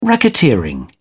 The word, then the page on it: racketeering: British English